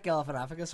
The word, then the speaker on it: Galifianakis: Fuck, it's Zach Galifianakis or whatever the fuck you pronounce his name.